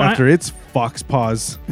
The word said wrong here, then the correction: faux pas: Faux pas. The way it's spelled, fox paws.